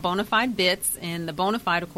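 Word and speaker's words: Bona5d: it's spelled B-O-N-A, the number 5-D. It is pronounced bonafide,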